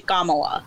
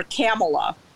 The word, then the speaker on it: Kamala: And it's basically Kamala. That's how she pronounces it. And so Tucker Carlson, a couple days ago, um, pronounced her Kamala and so my older sister was uh,